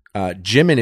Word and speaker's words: Jimin: And the Jimin is in it, and he's so beautiful in it.